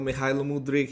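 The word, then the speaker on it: Mykhailo Mudryk: But anyways, excited and nervous about the derby, but everyone's talking about Mudryk and how, you know, Mihailo Mudryk, he's gone to Chelsea. – Great pronunciation, by the way. That's perfect.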